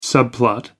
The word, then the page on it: subplot: American English